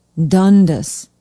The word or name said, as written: dundas